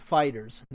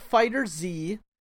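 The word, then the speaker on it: FighterZ: Yeah, see the Bandai Namco UK Twitter account says we can confirm that officially we pronounce it like the word fighters, not Fighter Z, not Fighter Z, not Fighter Zeto.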